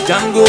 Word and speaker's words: Django: Them say them bad like Django, the Django. Them no say Django, no bad like the Django. But it a rhyme with mango.